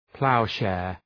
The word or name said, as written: plowshare